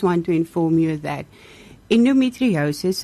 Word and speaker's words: endometriosis: So, Sister, this morning we're talking about endometriosis awareness. Am I saying it right? – Yes.